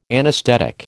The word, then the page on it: anaesthetic: American English